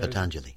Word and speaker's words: Patanjali: There's an Indian philosopher, Patanjali, is that how you say Patanjali. – It? Patanjali. Patanjali, I always mispronounce that. – That's all Patanjali.